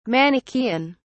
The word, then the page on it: manichaean: British English